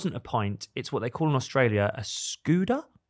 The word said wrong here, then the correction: schooner: Have you heard this? – Or schooner? – Schooner. That'd be it.